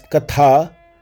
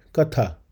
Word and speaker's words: katha: If it is pronounced as katha with too much stress on the letter th, which means distress in Sanskrit. So, this Upanishad is a story of the young boy named Nachiketa who was in utter distress with all his questions for Yama. But if the word is pronounced with soft th, th then it means a story or a legend, katha.